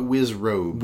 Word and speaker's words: Wizrobes: The Wizrobes? – The Wizzerbees. – Wizzerbees? – Yeah. That's what, aren't they called Wizzerbees? – I think you might be talking about Wizrobes.